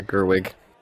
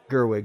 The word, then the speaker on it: Gerwig: Sorry, I'm trying to make sure I say her name properly. – Yeah, Gerwig. – Gerwig, excuse me, and her team, her assembly, whoever, your posse, whoever.